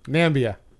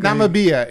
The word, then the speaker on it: Namibia: He mispronounced a country. He he meant to say... – Because then I'm reading the article. – Namibia is the country.